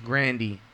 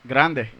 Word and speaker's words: Grande: I believe she said the name came from her grandfather, and it was pronounced Grandy. – Where is her? – But we just pronounce Grande because Grande. – Yeah. That's just how we do it.